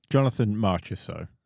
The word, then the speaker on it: Marchessault: And the entire commentary bench are just talking and going on and on again about Mercholat, or however you pronounce it. – How do you pronounce it? – Jonathan Marchessault is who you're talking about.